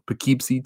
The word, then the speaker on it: Poughkeepsie: Poughkeepsie Tapes.